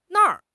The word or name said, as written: na4 r5